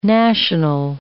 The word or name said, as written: national